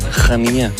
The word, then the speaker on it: Rania: Jeg har simpelthen kommet for mange beskeder til, at jeg ikke lige skal sige, det er udtalt som bare Rania.